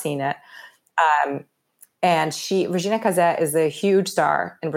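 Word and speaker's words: Casé: Like, Regina Casse, or Regina Case, if you're looking at it phonetically, if you're wondering who I'm talking about, is unbelievable.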